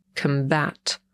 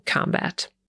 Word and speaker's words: combat: I tutaj wymowa nam się już dosyć znacznie zmienia, bo po brytyjsku ten czasownik akcentujemy na drugą sylabę, combat, a po amerykańsku czytamy tak samo jak rzeczownik, czyli combat.